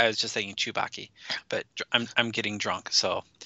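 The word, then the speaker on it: Tsubaki: And so Henry Gold did the voice of the Jedi Tuzbaki. – It's Sue. It's like Sue. It's kind of like it's pronounced as Z. The T-S is like a Z sound.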